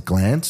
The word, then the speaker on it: Glanc: So my name is spelled GLANC. – Yeah. – But you pronounce it Glantz.